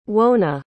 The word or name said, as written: wauna